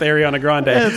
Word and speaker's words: Ariana Grande: But, you combine a Game of Thrones character with Ariana Grande, which I love it.